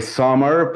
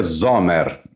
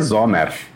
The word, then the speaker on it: Zomer: Eu falei Summer porque em inglês é Summer, mas a pronúncia é Zomer. A pronúncia é Zomer. – É com um S ou com Z? – É alemão. – Então é Zoma. – Não, mas em hebraico é Zomer.